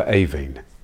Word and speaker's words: Aibhín: Since releasing this episode, it's been brought to our attention that we've been mispronouncing the name of the poem. It should, of course, be A Kite for Aibhín.